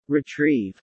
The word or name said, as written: Retrieve